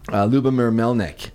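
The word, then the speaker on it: Lubomyr Melnyk: You mentioned Lubomir Melnik.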